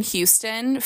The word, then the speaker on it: Houston: So like I lived in Houston for a couple of years and I pronounce it Houston. Like I dropped the H.